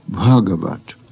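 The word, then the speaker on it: Bhagavat (भगवत्): The Sanskrit words should be pronounced in the same way. The first example is Bhagavat, meaning illustrious, divine. The final T becomes D when placed before the hard G, as in Gita.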